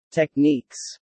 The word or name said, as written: techniques